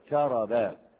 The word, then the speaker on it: كرب: فكرب، الكاف مفتوحة والراء مفتوحة والباء مفتوحة، نطقها هكذا كرب.